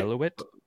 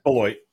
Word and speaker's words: Beloit: Beloit? How do you say it? It's Beloit.